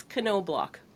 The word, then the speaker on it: Knoblauch: It's Knoblauch, but most people butcher it.